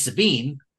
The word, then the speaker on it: Sabine: Rhymes with Shmameen. – Yeah. So, obviously, he's implying that it's Sabine.